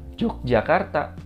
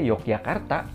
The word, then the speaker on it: Yogyakarta: J O J Yogyakarta. Jadi bacanya jangan Yogyakarta gitu karena dulu J dibaca Y ya bacanya Yogyakarta gitu